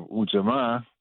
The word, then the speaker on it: Ujamaa: And now we have Ujamaa, which is the actual proper pronunciation, cooperative economics.